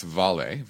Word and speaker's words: Vale: I believe it's pronounced Vale. V-A-L-E is the symbol.